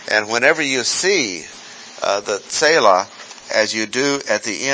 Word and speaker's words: Selah: And notice at the end of a statement about confidence, he says, S-E-L-A-H, Selah. We call it Selah in English, but that's not the Hebrew pronunciation. Selah.